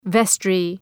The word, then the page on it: vestry: ‘vestrı